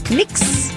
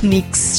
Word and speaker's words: Knicks: Rafa, tô atualizando aqui o nosso feed, a gente volta a falar de esporte, porque Knicks e Seleção Brasileira, eu falei certo? Knicks? – Falou certinho. – É assim que pronuncia? – Falou certinho.